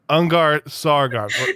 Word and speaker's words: Ungar-Sargon: We just learned the proper pronunciation. Ungar-Sargon. Yes? – That was so perfect.